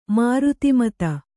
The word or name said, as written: ಮೂರ್ತಿಮತಿ